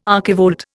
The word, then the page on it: archivolt: Indian